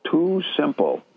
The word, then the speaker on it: TuSimple: there is a company called Too Simple.